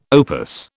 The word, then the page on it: opus: British English